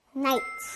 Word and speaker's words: night: Night. – 잘했어요. 밤, night. 여기도 GH가 들어가지만 발음은 없어요.